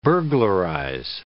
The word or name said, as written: burglarize